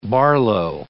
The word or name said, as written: Barlow